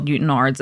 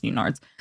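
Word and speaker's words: Newtownards: I was browsing TikTok and we have a place in Northern Ireland called Newton Ards. It's spelled Newtown Ards, but it's pronounced Newton Ards.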